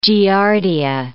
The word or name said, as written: giardia